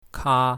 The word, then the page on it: ka1: mandarin